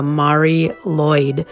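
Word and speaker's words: Mari Lwyd: And I came across something called, and this is two words, the Mari Lloyd. That's spelled M-A-R-I, and then the second word is spelled L-W-Y-D.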